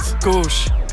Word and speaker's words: Gusch: Gusch. Es ist irgendwie das U ein bisschen kürzer und man muss es schon mit einem gewissen Wumms sagen, auf jeden Fall.